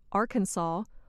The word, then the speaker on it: Arkansas: The pronunciation Arkansas follows the French custom of not pronouncing the final S.